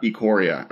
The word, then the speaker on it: Ikoria: It is Ikoria or Ikoria. Do we know? Has there been a pronunciation? – Ikoria. – Okay, it's Ikoria.